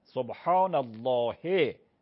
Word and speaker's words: سبحان الله: سبحان الله یا با حرکت یا وقفش میکنی سبحان الله، هش حتما باید تلفظ بشه.